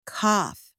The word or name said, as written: cough